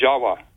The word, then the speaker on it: Jawa: Furthermore, appending the suffix T-I-S, the word Atlantis, it also is spelled T-I-V or pronounced T-I-W. The V's are pronounced as W's in Dravida, such as in Java. The word in Java, the island of Java, you know, is pronounced Jawa, actually. Indonesians will pronounce it Jawa, not Java like we do.